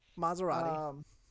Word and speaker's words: Maserati: Maserati.